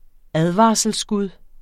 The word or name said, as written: advarselsskud